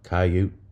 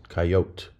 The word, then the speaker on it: coyote: Two syllables, coyote, coyote.